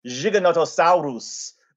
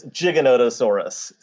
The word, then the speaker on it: Giganotosaurus: For example, I don't call it Giganotosaurus, which Rudolfo Coria would say. It's an Argentine pronunciation. It's Giganotosaurus. So that would be the way that most American paleontologists would say it, rather than Giganotosaurus.